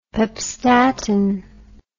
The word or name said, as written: pepstatin